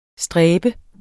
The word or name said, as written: stræbe